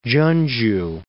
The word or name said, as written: Chonju